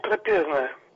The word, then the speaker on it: трапезное: Вы знаете, я давно уже задумываюсь о ударениях, которые по-разному поставлены в этом слове. То есть в одних случаях от верующих людей можно слышать «трапезное», а в других случаях «трапезное». Если мы с вами обратимся к словарю, то с греческого слова «трапеза», а именно ударение делается на «а», «трапеза», означает «стол».